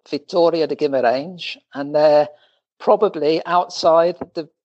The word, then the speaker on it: Guimarães: How do you pronounce this place? – It's not so easy. Guimarães. – Guimarães. – Guimarães, that's right, yeah.